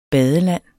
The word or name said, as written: badeland